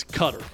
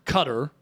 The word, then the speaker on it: Qatar: Basically, it has like a relative that lives in Qatar, and they're pronouncing it in Arabic, and it's a accent that I cannot pronounce. But it sounds like Qatar. – The emphasis is on Qatar. They're saying, like, Qatar. – I'm not conceding this one. – Here is where I'm coming from. Unilaterally, we know Mexico is Mexico. But if we have to ask the question if it's Qatar or Qatar, to me... – Well, Davey's explanation is that the English pronunciation is Qatar.